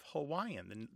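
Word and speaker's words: Hawaii (Hawaiʻi): In the phonetic inventory of Hawaiian, the Hawaiian language, a glottal stop is a part of it. The traditional pronunciation of the state is Hawaii, with a glottal stop in there.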